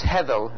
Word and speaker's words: hevel: And then I'm going to put in the Hebrew word, which means vanity, which is hevel, is the way that's pronounced.